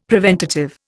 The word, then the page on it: preventative: Indian